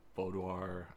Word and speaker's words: boudoir: So you're going to tackle writing a book, doing a boudoir, did I say that right? – Yes.